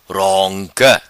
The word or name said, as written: Ronca